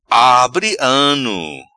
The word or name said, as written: Abri Ano